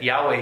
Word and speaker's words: Yahweh: I just did a PowerPoint when we were in America a little while back on the name of Yahweh, clearly showing that Yahweh is the way that the name is pronounced and not like these other exotic ways.